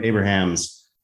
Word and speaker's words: Abrahams: Is that how you say his last name, or is it just Abrams? It would have to, I think it's Abrahams, right? – It's spelled out Abrahams. – Right. – I think I've always heard it as Abrams. It's not like J.J. Abrams, but I think that's how I've heard it too.